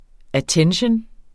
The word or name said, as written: att.